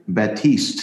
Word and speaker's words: Batiste: When you hear that piano, that's Bastis. Did I say his name right? Batiste, sorry.